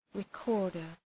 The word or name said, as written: recorder